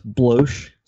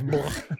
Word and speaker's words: Bloch: I believe it's pronounced Bloch, Scott. – I really don't know if it's Bloch or Block. – It's Bloch.